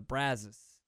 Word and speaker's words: Brazos: It's got to basically rhyme with Palace, the way J-Mo says it. Palace on the Brazos. It almost rhymes. Brazos ain't even close to rhyming.